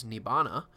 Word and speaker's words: Nibbana: Nirvana, or pronounced in Pali as Nibbana, is a void.